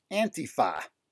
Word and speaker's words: Antifa: They used to be pronounced Antifa, which at least is more redolent of the actual root of the term, anti-fascist. Somehow this, you know, neologistic, newfangled pronunciation, Antifa,